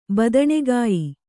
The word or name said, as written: ಬದನೆಕಾಯಿ